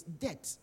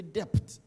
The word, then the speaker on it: debt: But sometimes your pronunciation, now that word, it's debt. We don't say depth, debt. You keep on saying depth. It's debt, yeah? D-E-B-T, the B is silent.